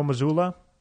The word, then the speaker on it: Mazzulla: Coach Joe Mazzulla.